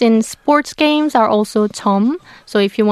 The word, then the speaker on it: 점: 점. And know when to pronounce it with the double J.